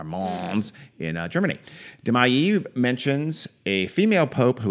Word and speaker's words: Mailly: The last name is spelled M-A-I-L-L-Y. So I'm saying Mailly because it just seems very French to me. – It's Mailly.